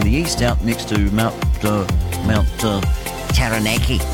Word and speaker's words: Taranaki: Taranaki.